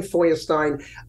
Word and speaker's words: Feuerstein: Joe is in my name, Feuerstein. Feuer, which is spelt German for fire, and Stein is in stone, because it's really Flintstone.